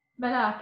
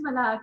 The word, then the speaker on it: Malak: Malak, yeah, my name is Malak, but I'll answer to both, yeah, especially on air.